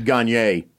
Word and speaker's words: Gagne: I was like, Gagne, I think of the Dodgers closer back in the day. – Gagne is actually how they pronounce it.